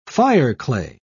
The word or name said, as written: fireclay